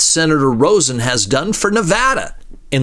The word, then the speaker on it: Nevada: Nevada. You know, that's how most people back east and around the world say it, right? Nevada. – It's Nevada, sir. Please say it correctly.